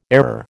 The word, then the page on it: error: American English